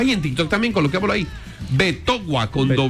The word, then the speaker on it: Betowa: ¿Cómo se escribe Betoua? – B-E-T-O-W-A. – Ah, con W. – Y escribe así, Betoua Cena.